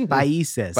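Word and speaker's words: Países: There's the accento over the I. It's Paises.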